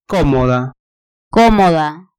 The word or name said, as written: comoda